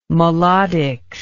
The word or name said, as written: melodics